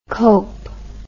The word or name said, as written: cowp